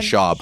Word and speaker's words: Schaub: How do you say the name? – Brendan Schaub.